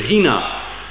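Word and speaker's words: Hina (ἵνα): It's the Greek word hina. We spell it in English, H-I-N-A. There's no H, of course, in Greek. And it's a silent H shown by a little comma above the I.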